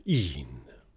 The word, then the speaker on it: even: And note that even is pronounced with one syllable here, een.